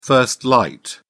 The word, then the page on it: first light: British English